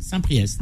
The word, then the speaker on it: Saint-Priest: Comment ça se prononce Saint-Priest Saint-Priest. Oui.